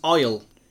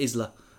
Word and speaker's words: Isle: Oh, it's Isle. Isle of Beans. I thought it was going to be Isla.